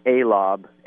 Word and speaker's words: A-Lob: So they gave me this nickname, A-Lob, A-L-O-B, which has kind of stuck for me my whole life.